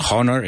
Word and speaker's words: honor: Honor, fijaos en la pronunciación, no es honor, it's honor.